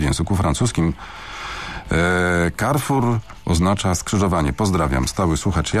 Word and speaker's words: Carrefour: Poprawnie należy wymawiać nazwę francuskiej sieci super i hipermarketów Carrefour. Należy przy tym akcentować ostatnią sylabę słowa, pisze słuchacz. Zgodnie z zasadami wymowy C przed samogłoską czytamy jako K, a O, U jako U. E jest nieme.